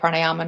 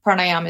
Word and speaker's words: Pranayama: Pranayama is the breathing technique. It's also pronounced Pranayama, and it's spelt Pranayama. I pronounce it Pranayam.